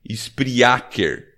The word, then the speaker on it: Spreaker: Só que o Spreaker se escreve spriaker, a portuguesano e eu vou soletrar é S-P-R-E-A-K-E-R.